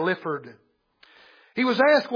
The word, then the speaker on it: Lyford: His last name may be pronounced Lifford. It's spelled L-Y-F-O-R-D.